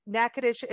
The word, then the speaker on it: Natchitoches: Now, if you have not been to Natchitoches, you may know, or you may not know, that it Natchitoches is spelled N-A-T-C-H-I-T-O-C-H-E-S. So it's a little bit different than how we pronounce it.